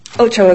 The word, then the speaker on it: Ochoa: Ochoa is fine. Okay. Angela Ochoa. – Thank you. – My name is Angela Ochoa.